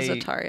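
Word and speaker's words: za'atar: Yeah, Zatar, yeah.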